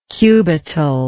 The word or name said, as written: cubital